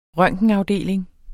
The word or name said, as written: røntgenafdeling